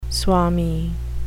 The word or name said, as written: swami